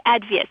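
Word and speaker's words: Advieh: And tell me a little bit about this spice mix called Advia. Am I saying it right? – Exactly, Advia.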